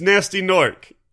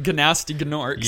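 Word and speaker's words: Gnasty Gnorc: It's Nasty Nork. – It is, but one of the dragons at least pronounces it Gnasty Gnork.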